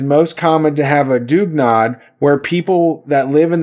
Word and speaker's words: dugnad: pronounced Dugnad, which is a form of volunteer communal labor.